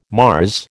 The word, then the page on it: Mars: American English